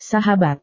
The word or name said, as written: sahabat